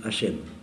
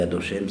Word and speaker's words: Hachem: Alors on dit Adoshem, ça fait trois syllabes. Mais c'est un barbarisme épouvantable. Enlevez-vous ça de la tête.